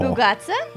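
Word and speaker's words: bougatsa (μπουγάτσα): And I asked how to pronounce that just for this occasion. – Oh. – Bougatsa.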